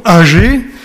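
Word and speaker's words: Aggée: Alors, je prononce Agé, alors que d'habitude, on dit plutôt Agé, parce qu'il y a deux G à Agé. Donc, pour le différencier peut-être du mot Agé, je dirais Agé, mais ça n'a aucune importance, vous pouvez très bien dire âgé si ça vous chante.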